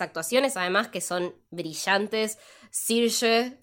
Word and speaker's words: Saoirse: Saoirse. – Saoirse, it's perfect. Saoirse like inertia.